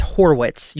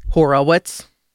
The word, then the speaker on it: Horwitz: By the way, it's Horwitz. You said it wrong on the headline, but just thought you'd like to know. – Thank you. – What did he say? – Horowitz.